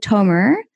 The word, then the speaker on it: Tomer: Is it Tomer? – Yes, that's right.